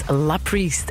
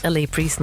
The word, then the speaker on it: LA Priest: Het is La Priest en wij zeiden de hele tijd LA Priest, maar het is dus La Priest.